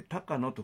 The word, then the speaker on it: 竹野: 竹野のと書いて高野と、